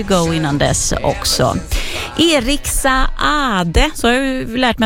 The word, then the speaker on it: Saade: Han stavar ju S-A-A-D-E, och då ska man tydligen uttala båda och en också, så då gör jag det.